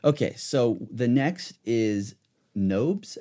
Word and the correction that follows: Nozbe: How do you pronounce it? Nobs?y Uh, Nozbe. Nozbe.